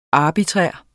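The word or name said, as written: ARBITRÆRT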